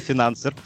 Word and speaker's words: финансер: То есть, получается, э, сейчас немножечко отвлекусь, не финансер, а финансер. – Почему? Финансер. – Да, а то мне показалось, что ударение сделал на Е, такая, думаю, что неправильно говорю, что ли? – Не-не-не, финансер.